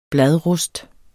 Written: bladrust

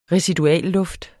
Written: residualluft

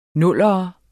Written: nuller

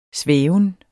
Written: svæven

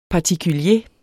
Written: particulier

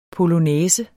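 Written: POLONÆSEN

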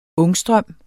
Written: ångstrøm

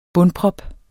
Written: bundprop

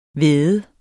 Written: væde

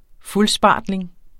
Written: fuldspartling

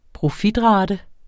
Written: profitrate

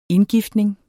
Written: indgiftning

